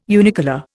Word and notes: unicolour: Indian

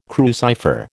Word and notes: crucifer: American English

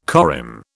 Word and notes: corymb: British English

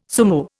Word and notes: sumo: Indian

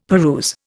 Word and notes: peruse: Indian

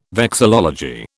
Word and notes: vexillology: British English

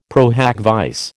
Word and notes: pro hac vice: American English

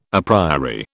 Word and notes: a priori: British English

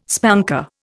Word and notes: spanker: Indian

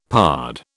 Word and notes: pard: British English

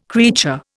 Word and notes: creature: Indian

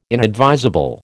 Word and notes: inadvisable: American English

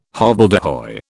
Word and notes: hobbledehoy: British English

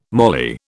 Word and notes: moly: British English

